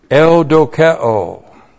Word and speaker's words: eudokeo: And then we have eudokeo. E-U-D-O-K-E long O. Eudokeo.